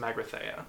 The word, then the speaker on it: Magrathea: The crew then sets out to look for the legendary planet Magaretha, which was known for selling luxury planets. – I think it's pronounced Magrathea. – Magrathea, thank you.